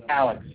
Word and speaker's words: Alex: Alex. – Alan. – Alex. – Can you spell it for me? – A-L-E-X.